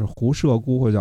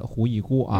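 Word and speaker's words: 狐射姑: 那么另外一个大臣呢，贾季，也就是胡射孤或者叫胡义孤啊。这个射到底是应该念成义还是念成射？应该是念成义的胡义孤啊。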